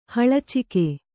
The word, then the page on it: ಹಳಚಿಗೆ: haḷacige